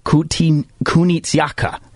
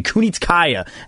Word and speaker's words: Kunitskaya: You got Irene Aldana and Yana Kunitskaya. Excuse me, Kunitskaya, as I always get these names mispronounced.